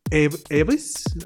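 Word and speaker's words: Eavis: Y fue creado por eh, Michael Eves, así se pronuncia.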